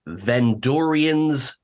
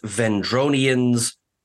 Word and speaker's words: Vendorians: But halfway through the ups and downs watching back, I realized it's Vendorians, not Vendronians.